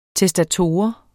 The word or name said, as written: TESTATOR